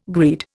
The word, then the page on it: greed: Indian